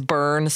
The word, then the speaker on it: Byrne: I feel like sometimes when it's like, you know, personal to you, like, for instance, my maiden name is Byrne, spelled like David Byrne, like B-Y-R-N-E.